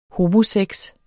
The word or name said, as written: homosex